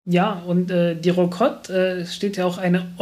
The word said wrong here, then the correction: Rokot: Also wenn du es richtig im Russischen aussprechen willst, dann ist es eine Rakot.